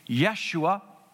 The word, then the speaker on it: Yeshua: The Hebrews would have pronounced that name Yeshua, which means Savior.